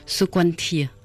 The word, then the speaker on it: Sokoantea: et mon prénom en entier d'origine se prononce Sokwantia, et ça s'écrit Sokoantea. – Et ça veut dire Sokwantia, ça veut dire parfum de fleurs.